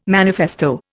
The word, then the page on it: manifesto: Indian